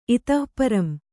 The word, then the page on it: ಇತಃಪರಂ: Kannada